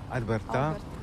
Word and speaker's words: Alberta: Comment on prononce ? – Alberta. – Alberta.